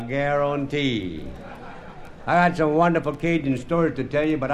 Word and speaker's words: Cajun: Cajun is a mutilation of the word Acadian. And you spell it generally C-A-J-U-N, pronounced Cajun in Mexico.